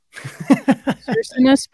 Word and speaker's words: Circinus: Serenus? – Serenus. Is that all right? Serenus? Are we pronouncing this right? – Circinus, probably.